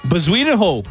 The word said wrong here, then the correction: Bezuidenhout: Oh, that's not even close. That's not even close. – Christian Bezuidenho. – No. – Bezuidenho. – Try again. – Bezuidenhout.